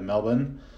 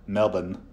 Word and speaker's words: Melbourne: We're in Frankston, Australia, which is about 45 minutes outside of Melbourne. Notice any Australians listening. I hope you appreciate that proper pronunciation of Melbourne, not Melbourne.